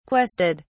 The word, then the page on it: quested: British English